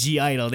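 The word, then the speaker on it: (G)I-DLE: After that, we had G Idol, and they confirmed the pronunciation was G Idol. They said G Idol. – Yes, they did. They said the G.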